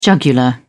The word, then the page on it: jugular: British English